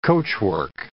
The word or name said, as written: coachwork